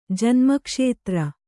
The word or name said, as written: ಜನ್ಮಕ್ಷೇತ್ರ